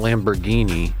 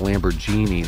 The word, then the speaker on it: Lamborghini: It's not Lamborghini. – Mm-hmm. – It's actually Lamborghini. That's genuinely how you would say it.